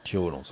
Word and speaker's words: Thiolon: It was self-titled, so Louise, Tion? – Toilon. – So that's spelled T-H-I-O-L-O-N. – Toilon. Toilon, sorry.